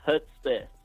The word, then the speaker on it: Hudspeth: It's Hudspeth. – Hudspeth. – Actually pronounced just how it's spelled, but it's still a challenge.